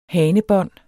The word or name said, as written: hanebånd